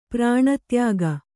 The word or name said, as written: ಪ್ರಾಣತ್ಯಾಗ